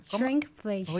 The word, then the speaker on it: shrinkflation: ¿Cómo se pronuncia lo otro? Crickflation. – Shrinkflation. Oye eso, Dios santo, todos los días uno aprende algo nuevo.